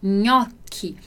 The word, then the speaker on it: gnocchi: E depois a gente tem, né, o clássico prato aí, gnocchi, gnocchi. E vai ter esse nosso som de NH no português.